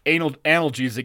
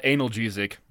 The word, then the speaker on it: analgesic: It's pronounced analgesic, not analgesic.